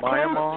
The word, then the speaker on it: Myanmar: It's Myanmar. – Close to Bangladesh and between Bangladesh and India. – Myanmar.